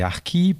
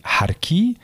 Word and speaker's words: harki: En effet, en Algérie, la situation est complètement différente de celle de la France en ce qui concerne les harkis, puisque déjà le mot ne se prononce pas comme je le prononce jusqu'à présent. Il se prononce avec un accent algérien, donc on dit harki. Et dès qu'on prononce ce mot à l'algérienne, harki, il faut se rendre compte qu'il est immédiatement chargé d'une immense violence aux oreilles algériennes, aux oreilles des 38 millions d'Algériens d'aujourd'hui.